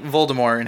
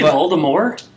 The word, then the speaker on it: Voldemort: Did you say Voldemort? There's a T at the end. – No, it's French. There's no T. I mean, there is a T, but it's not pronounced.